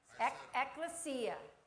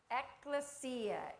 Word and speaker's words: Ekklesia: Ekklesia. Ekklesia is the name of it.